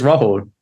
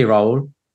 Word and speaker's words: Rahul: It's Rahul, but it can be Rahul. – Because I say Rahul, I think. – Yeah, that's fine. Yeah. I mean, there's a H in there. – So yeah. – It just depends, you know, like it really depends. – I'm allowed to pronounce the H.